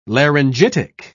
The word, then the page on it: laryngitic: British English